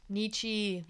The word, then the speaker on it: Nietzsche: Nietzsche, my boy.